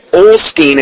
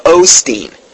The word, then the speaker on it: Osteen: I had actually had somebody email me and tell that to me, too, because because I had mispronounced Joel, and I said Olstein instead of Osteen.